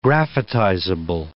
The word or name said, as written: graphitizable